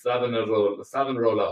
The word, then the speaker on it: Savonarola: Yeah, Savonaro Hotel. We'll leave the link in the description. Savonarola? I can't pronounce anything. – Savonarola Hotel.